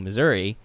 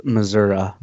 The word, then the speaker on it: Missouri: And I think that's like Three Billboards Outside Ebbing, Missouri with Rockwell's character. I think sometimes that's the point of movies, is to show a different side of people. And it's pronounced Missouri. – Missouri, sorry. Missouri.